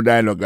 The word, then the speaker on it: dialogue: Comme n'a pas mis des accents, sous bel accent en anglais, sous belle prononciation en anglais, le mot dialogue a gagné deux syllabes là-dedans. Daily Dialogue, alors, il pèse plus sous premier syllabe là. Daily Dialogue. Alors, accent tombé sous premier syllabe là.